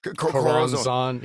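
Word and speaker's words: Choronzon: You know, Coronzone. How do you say? – Coronzone. Yeah.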